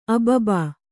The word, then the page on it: ಅಬಬ: ababa